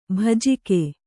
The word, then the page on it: ಭೋಜಕ: bhōjaka